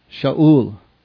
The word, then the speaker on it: Shaul: And after laying his hands on him, on Paul, said, "Brother," Saul would be the Hebrew pronunciation, "Shaul,